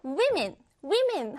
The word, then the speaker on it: women: 현재는 이 주머니가 아주 유용하고 모든 옷에 거의 달려 있는데, 그러나 long ago, 오래 전에는요, women 하면 우리 친구들 이렇게 여자들이라는 복수형입니다. woman 하면 여자 한 명이지요. 이 a를 e로 바꿔서 women이라고 발음하고 여자들이라고 쓸수 있어요.